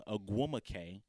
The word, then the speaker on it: Ogwumike: But it's Nneka Ogwumike.